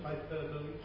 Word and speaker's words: hyperbole: Some are, how do you say it in English? Hyperbole? Hyperbole? – Hyperbole. – Hyperbole, alright.